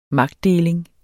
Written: magtdeling

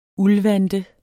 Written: uldvante

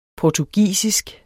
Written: portugisisk

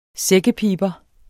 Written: sækkepiber

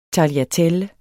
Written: tagliatelle

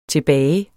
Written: tilbage

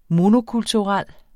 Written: monokulturel

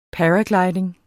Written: paraglidning

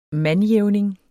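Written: MANDJÆVNINGERNES

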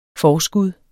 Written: forskud

